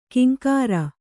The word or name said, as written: ಕಿಂಕರ